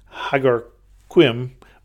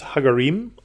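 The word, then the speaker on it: Ħaġar Qim: And in the western part of the island, these two that are right next to each other in the same site are, one is spelled H-A-G-A-R space Q-I-M, and it looks like Hagarquim, but it is Hagarim, apparently.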